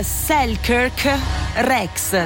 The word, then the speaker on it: Selkirk Rex: Adesso io chiedo perdono per la pronuncia, ma è il Selkirk Rex. Si scrive Selkirk con entrambe le K, quindi Selkirk Rex, ed è unico questo gatto perché ha il pelo riccio.